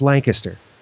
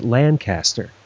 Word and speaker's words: Lancaster: because it's Lancaster, but to people who live there, but most people would pronounce it Lancaster.